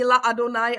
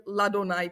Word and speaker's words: Ladonaj: A není La Adonaj, ale je Ladonaj. Prostě to je takový úzus, že se to tak vyslovuje. To je úzus Ladonaj, jo?